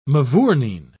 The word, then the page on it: mavourneen: British English